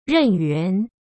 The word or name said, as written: Ren Yuan